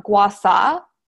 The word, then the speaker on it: gua sha: I was taught gua sha, which is like the traditional way of saying it, but the more like common way is gua sha.